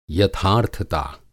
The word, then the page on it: यथार्थता: Hindi